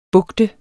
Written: bugtet

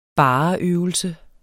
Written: bars

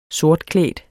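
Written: sortklædt

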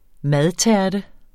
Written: madtærte

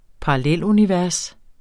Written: parallelunivers

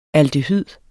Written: aldehyd

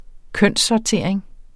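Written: kønssortering

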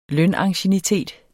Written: lønanciennitet

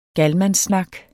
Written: galmandssnak

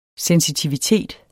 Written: SENSITIVITETEN

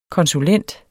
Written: konsulent

